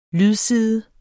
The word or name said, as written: lydside